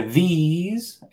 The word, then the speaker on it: these: Pues a mí me gusta explicarlo como que this es más, es más corto, ¿no? This. Mientras que these es más largo, ¿no?